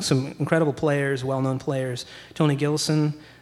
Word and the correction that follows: Gilkyson: Um, I'm pronouncing that correctly. Guitar. – Gilkyson, yeah. – Gilkyson, yeah. Uh,